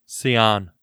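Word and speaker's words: Ceán: How would you say this name? C-E-A-N. – Cian. – Nice. I like it.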